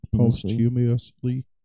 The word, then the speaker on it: posthumously: Posthumously, how do you say it? – Posthumously.